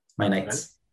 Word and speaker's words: My Nights: Son cinco minutos de My Night y My Nights, ¿no? Con ese. My Nights. en plural, en plural, lo he dicho mal.